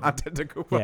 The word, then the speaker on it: Antetokounmpo: We all learned how to say Giannis Antetokounmpo. – Did we? – Did I just ruin my own point? – You probably did. – Antetokounmpo. – Yeah, good job.